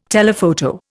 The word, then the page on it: telephoto: Indian